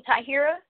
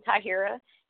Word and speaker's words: Tahiyrah: So you can find me on Facebook as Queen Tahira, New Power Radio Network, and Sign of the Times, on Twitter at Queen Tahira, and Queen underscore Tahira on Instagram. And Tahira is spelled T-A-H-I-Y-R-A-H.